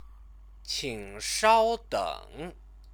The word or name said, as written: qingshaodeng